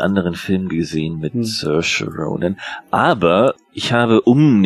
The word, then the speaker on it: Saoirse: Saoirse Ronan, mhm.